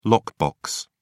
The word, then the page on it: lockbox: British English